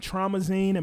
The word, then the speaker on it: Traumazine: Meg Thee Stallion, Traumazine, am I saying that right? – Yeah.